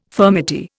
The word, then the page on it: furmety: Indian